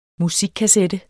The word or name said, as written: musikkassette